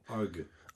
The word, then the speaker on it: Argue: On essaiera de l'écouter en fin d'émission, pour Darcy James Argue. Je sais pas si on le prononce comme ça, d'ailleurs. Si c'est bien Argue, vous pensez? – Argue. – Arg, c'est très bien aussi.